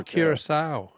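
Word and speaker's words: Curaçao: I don't know if I'm pronouncing it right, but I thought it was Curacao. – Oh, Curacao. Yeah. – Yeah. – No, that's very good. I'm sure you're pronouncing it correctly, but that's not...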